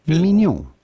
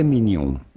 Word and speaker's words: mignon: Mignon. Filet mignon. – Mignon. – But it'd be fine in English. – You're pretty good. – Mignon.